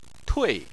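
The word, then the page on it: tui: tone 4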